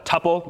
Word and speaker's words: tuple: The other important sequence data type that we'll often see is called a tuple or a tuple. You'll hear it pronounced both ways.